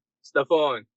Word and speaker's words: Stephan: I'm pronouncing Steven correct. That's the pronunciation. – Stefan. – Stefan, my apologies.